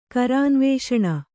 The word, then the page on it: ಕರಾನ್ವೇಷಣ: Kannada